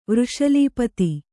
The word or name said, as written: ವೃಷಲೀಪತಿ